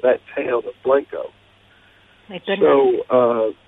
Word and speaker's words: Blanco: I will pronounce it Blanco. We Texans call it Blanco.